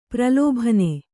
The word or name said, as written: ಪ್ರಲೋಭನ